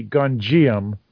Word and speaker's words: Gonjiam: So basically, if we pronounce it phonetically, I guess it would be Gonjiam, because it's G-O-N-J-I-A-M, Haunted Asylum, 2018 film.